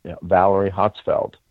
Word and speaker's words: Valerie Hotzfeld: So we'd like to introduce our Amateur of the Year, Valerie Hotzfeld, NV9L. And she, did I say it wrong, Valerie? – No, you said it perfectly.